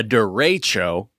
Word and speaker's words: derecho: But they call it a derecho, according to the pronunciation guide on CNN, so you can really sound like a person who doesn't know where the origin of that word is.